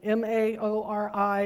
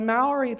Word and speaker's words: Maori: These are the indigenous people of New Zealand, M A O R I, and most of the people I heard pronounce it Ma- Maori, the R sounds like a D. I can't quite uh, pull that one off.